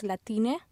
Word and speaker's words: Latinx: And when you say Latin X, it's basically pronounced Latine.